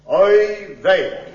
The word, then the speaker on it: oy vey: The word is oy vey.